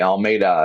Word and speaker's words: Almeida: On the computer, I have Joseph Almeida. And I'm saying it right, I hope. Perfect. All right, Almeida. That's it.